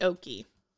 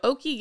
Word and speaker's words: Oke: And then we meet Oki. – Oh, yeah. – I think that's how you say his name. It's spelled O-K-E.